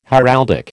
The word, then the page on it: heraldic: American English